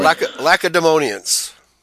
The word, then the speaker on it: Lacedaemonians: Lacedaemonians.